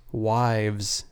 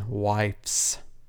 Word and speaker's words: wives: I've noticed that you pronounce wives as wifes, as in... – With an F? – Yeah, like W-I-F-E-S. Which is not correct.